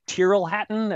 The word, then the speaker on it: Tyrrell Hatton: We lost $50 on Adam Scott, but we won $70 on, is it Tyrrell Hatton? Am I pronouncing it correctly? – That's correct. Very good.